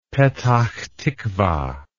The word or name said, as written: petachtikva